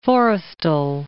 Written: Forrestal

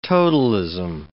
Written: totalism

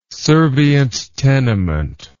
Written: servient tenement

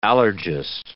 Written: allergist

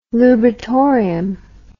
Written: lubritorium

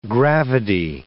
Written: gravidae

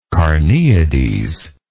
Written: carneades

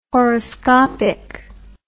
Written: horoscopic

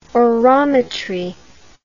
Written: orometry

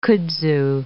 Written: kudzu

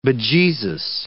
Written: bejeezus

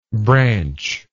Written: branchlike

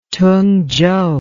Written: tungchow

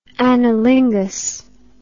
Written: analinguist

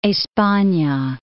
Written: Espana

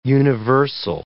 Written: universal

